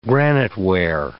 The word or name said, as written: graniteware